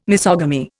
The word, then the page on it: misogamy: Indian